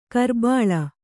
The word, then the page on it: ಕುರಿಬಳ್ಳಿ: kannada